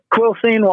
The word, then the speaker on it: Quilcene: Now I'm glad that you said your hometown, Quilcene. Is that how you pronounce it? – Yep, Quilcene, Washington.